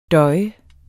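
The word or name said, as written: døje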